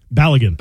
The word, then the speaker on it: Balogun: As the World Cup continues, FIFA makes a stunning reversal, ruining that American star scorer, Falerin Balogun. Is that how to pronounce his name? – Balogun. – Balogun. I always get that mixed up.